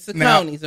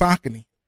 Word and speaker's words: Saucony: Which we get Sacconis now. – Now we all thought it's actually pronounced Saucony.